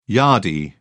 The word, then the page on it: yardie: British English